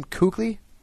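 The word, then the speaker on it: Kuechly: Is that how you pronounce his name, Kukli? – Yeah, just like you, except we put the L in.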